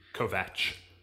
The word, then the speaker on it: Kovacs: Kovach. It's spelled Kovacs, but it's pronounced Vach.